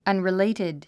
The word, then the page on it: unrelated: アンリレイテッド